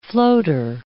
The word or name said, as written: floater